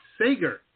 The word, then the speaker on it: Sager: Sager.